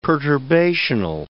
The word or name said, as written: perturbational